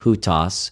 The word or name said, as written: οὑτος